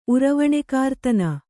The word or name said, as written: ಉರವಣೆಕಾರ್ತನ